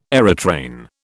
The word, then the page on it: aerotrain: British English